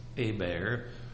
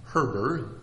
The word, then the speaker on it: Herber: That name Herber is a French name, and I guess if you're speaking proper French, it ought to be pronounced Hébert, but we in my hometown didn't pronounce it that way. We called him Herber,